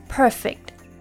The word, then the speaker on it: perfect: Here because we are using it as an adjective, the stress is on the first syllable, perfect.If it's used as a verb, then the stress is on the second syllable, perfect, to perfect a skill.